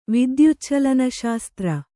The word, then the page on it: ವಿದ್ಯುಚ್ಛಲನಶಾಸ್ತ್ರ: Kannada